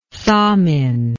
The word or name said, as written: thamin